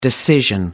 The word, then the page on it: decision: American English